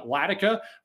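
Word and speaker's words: Ladika: You can also follow my personal Twitter account at Ryan Latica.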